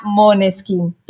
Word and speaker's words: Måneskin: Y bueno, ellos son muy buenos, pero ese nombre se pronuncia Måneskin porque sale de danés, el idioma de Dinamarca, como dicen ustedes, ¿verdad?